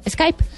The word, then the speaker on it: Skype: Una vez entrevistamos a una persona que trabaja en Skype y le preguntamos cómo se dice Skype o Skype y dijo Skype.